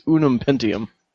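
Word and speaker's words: Ununpentium: It's Unum Pentium.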